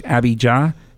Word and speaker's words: Abijah: One last thing to mention too is that Zechariah belonged to the priestly division of, I'm going to mispronounce this, Abijah. Is that correct? Abijah? A-B-I-J-A-H. That's how you pronounce it.